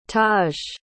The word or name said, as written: tahj